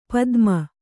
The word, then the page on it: ಪತಮ: kannada